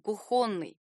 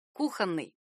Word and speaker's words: кухонный: Прилагательное «кухонный», например, очень часто произносят неправильно — «кухонный». Наверняка в мебельных магазинах вы слышали о кухонных гарнитурах, а с друзьями вспоминали кухонные споры, видимо, по аналогии с вагонными. Но орфоэпические словари отмечают вариант «кухонный» как недопустимый. Правильно только «кухонный».